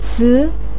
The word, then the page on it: ci2: pinyin